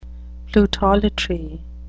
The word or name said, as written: plutolatry